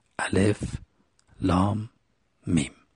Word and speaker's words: الم: نخستین آیه این سوره الف، لام، میم هست، سه تا حرفه و این سه تا حرف جدا جدا و بریده بریده قرائت میشه، الف، لام، میم.